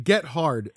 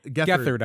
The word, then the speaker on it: Gethard: It's like Get Hard. – Gethard, I would say, probably. Gethard. – Of the Chris Gethard show, The show's host, Chris Gethard.